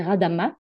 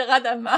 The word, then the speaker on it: Radama: Alors, euh, tous les noms à Madagascar qui finissent en A, tu dis pas le A à la fin. ça s'écrit Radama, mais on dit Radam.